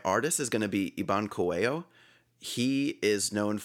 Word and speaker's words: Iban Coello: Iban Coelho. So I-B-A-N-C-O-E-L-L-O.